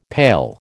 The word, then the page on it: pail: American English